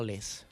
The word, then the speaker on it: Olise: Usted sabe la pronunciación. – Nació, no raíces. – Por eso nació. – El apellido es británico. – Nació en Londres. – ¿Y cómo usted lo diría? – Repítalo. – Michael Olise.